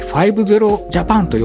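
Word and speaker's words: 00000JAPAN: 05 とジャパンと書いて 50 ジャパンと読むんですけども、面倒な設定やパスワードがいらない Wi-Fi なので、誰でも簡単に使えるようになります。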